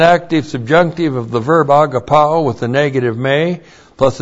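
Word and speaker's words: agapaō: The verb agapao A-G-A-P-A,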